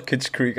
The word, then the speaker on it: Kitschkrieg: Ja, es heißt doch Kitschkrieg, aber also es hat, du hast gerade so Deutsch ausgesprochen, weiß ich nicht.